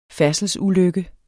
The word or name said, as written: færdselsulykke